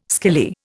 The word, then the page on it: skilly: Indian